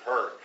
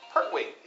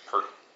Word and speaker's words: Pertwee: It's Pertwee. You said Pert. – Pertwee. – Yeah, see, that's Pertwee.